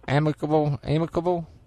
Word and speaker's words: amicable: But everything's, how you pronounce it, amicable, whatever you say. – Amicable? Yeah, something like that. Yeah, amicalola. – Now I can say amicalola. Yeah. I can't say amicable. – All right. – Amicable and amicable. I can spell it. You mean to spell it for you? A-M-I-C-A-B-L-E. How's that? – Now I can say that. Amicable. – Okay, that's what it is.